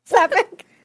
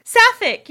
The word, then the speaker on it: sapphic: Septic? How do you pronounce this? – Septic tank? I know. Wait, what were you going to say? No. What were you going to say? – Sapphic. – Sapphic, yeah, like sapphire, sapphic.